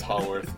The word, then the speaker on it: Haworth: Both are fine. Yeah, I guess technically it's Haworth, but you're supposed to say it like we're a pretentious British person. Like Haworth.